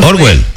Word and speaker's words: Orwell: Bueno, Orwell. – Fue, Orwell, sí.